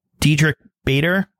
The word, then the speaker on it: Diedrich Bader: That was a couple years ago going back, but that was with Diedrich Bader. Am I saying his name right? I always mess up his name. – You are, yep.